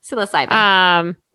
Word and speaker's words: psilocybin: Yeah, psilocybin.